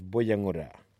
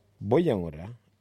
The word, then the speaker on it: Buyungurra: Boyangura is spelt B-U-Y-U-N-G-U-R-R-A. Boyangura. Can you say Boyangura?